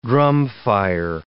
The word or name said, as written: drumfire